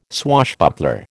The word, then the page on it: swashbuckler: American English